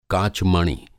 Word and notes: काचमणि: Hindi

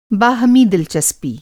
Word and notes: باہمی: बाहमी

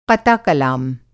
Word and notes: क़ता-कलाम: قطع کلام

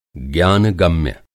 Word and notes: ज्ञानगम्य: Hindi

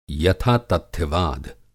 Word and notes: यथातथ्यवाद: Hindi

